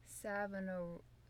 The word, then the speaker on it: Savonarola: Savonarola.